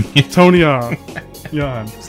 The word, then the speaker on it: Tonyan: Is that how you say it? Tonium? – What's a Tonyan? – Tonyan. Yon.